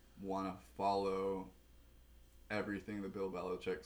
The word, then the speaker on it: Belichick: I'm going to pronounce it the proper Croatian way. Bill Belichick. I was expecting a little more sauce from the big guy.